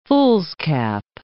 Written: fool's cap

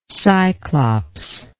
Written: cyclops

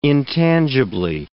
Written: intangibly

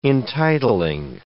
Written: entitling

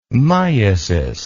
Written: myasis

